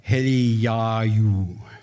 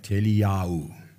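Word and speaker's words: Eliyahu (אליהו): If you're Hebrew, you'll pronounce Elijah Eliyahu, something like that, Eliyahu, Elijah.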